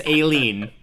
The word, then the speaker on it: Aileen: So everybody listening, it's Aileen, and you're making Aileen unhappy.